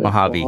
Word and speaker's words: Mojave: And just dipped my toes into California over by where the, the Mojave Desert is that how you pronounce it? Mojave. – Mojave Desert. Mojave Desert, yeah, that.